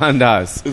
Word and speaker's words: Andaz: I'm here at the beautiful Andaz Hotel. – I thought it was called Andy's. – No, it's called fucking Andaz. It's called Andaz. – It's called Andaz? – Yeah, Andaz.